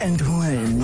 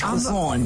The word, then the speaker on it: Antoine: No, say it like you said it when you say you're like, Antoine. You sounded like you're French. Antoine. – Because I gotta sound French.